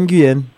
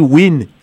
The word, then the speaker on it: Nguyen: Nguyen, ça s'appelle Nguyen. – Ok, moi. Lee Nguyen, pas Lee Win.